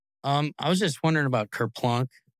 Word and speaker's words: Kerplunk: Is it Kerplunk? – Yes, Kerplunk.